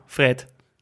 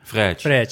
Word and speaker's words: Fred: En dan hebben we natuurlijk nog wel, ja, op zijn Nederlands uitgesproken, vind ik het mooier, Fred. – Ja. Maar op zijn Braziliaans. Fred.